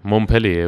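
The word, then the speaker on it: Montpellier: الاخيره مونبلي ولا اه صح عندهم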